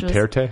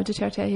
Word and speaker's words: Duterte: Which Duterte? Was, is that how you pronounce it? Yeah, I think so. Duterte.